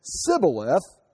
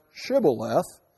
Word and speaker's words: Shibboleth: If he said Sibboleth, because he could not pronounce Shibboleth, they seized him and killed him. So say Shibboleth, Sibboleth.